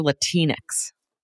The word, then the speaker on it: Latinx: But if you use these signifiers of, you know, Latinx, is it Latinx or Latinx? – Latinx rhymes with Kleenex.